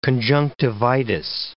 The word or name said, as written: conjunctivitis